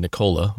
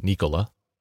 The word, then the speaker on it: Nikola: For the record, he said Nikola was correct, or at least acceptable, instead of Nikola, to generally honoring the great man that he was.